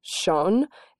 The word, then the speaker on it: Sjón: And the author's name, I think, is pronounced Sjón. It's S-J-O-N.